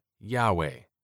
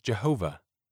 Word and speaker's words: Yahweh: No one today knows for certain how to pronounce this name, but the most commonly accepted pronunciation now is Yahweh. A common, though erroneous, earlier rendering was Jehovah.